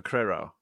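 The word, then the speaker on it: Crerar: I'm joined now by The Guardian's political editor, Pippa Criro. – Hi. Hi, John. Hi. Hello. Thanks for having me. – How was the surname pronunciation this week? – Pretty good. You're getting better every time.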